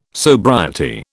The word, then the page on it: sobriety: British English